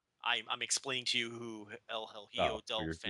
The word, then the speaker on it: El Hijo del Fantasma: El Hijo del Fantasma. That was not even there it is, that El was Hijo. That was not even hard, Rich.